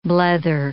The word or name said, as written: blether